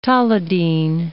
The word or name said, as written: tolidine